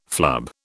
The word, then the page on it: flub: British English